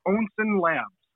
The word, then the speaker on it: Onsen Labs: We pronounce it Onsen Labs. And Onsen is actually like a natural hot spring.